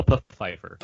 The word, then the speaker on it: Pfeiffer: It's pronounced Michelle Pfeiffer.